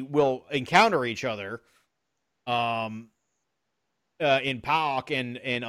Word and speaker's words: PAOK: PAOK, I think. – PAOK. An actual Greek person pronounced it right. PAOK to me, so.